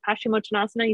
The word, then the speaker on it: Paschimottanasana: When you said Paschimottanasana, you said it correctly.